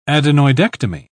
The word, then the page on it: adenoidectomy: British English